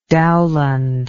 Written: Dowland

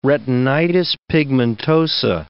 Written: retinitis pigmentosa

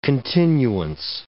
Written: continuance

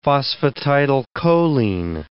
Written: phosphatidylcholine

